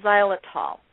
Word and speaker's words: xylitol: But this sugar is called xylitol. It starts with an X, and I'm going to spell it for you, X-Y-L-I-T-O-L.